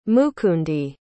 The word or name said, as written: mukundi